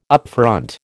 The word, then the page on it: upfront: American English